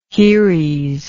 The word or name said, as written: heres